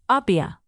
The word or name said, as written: Apia